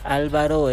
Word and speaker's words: Álvaro: Y también en algunos lugares vi de que Álvaro lleva acento en la A. – Álvaro. – En la primera, sí.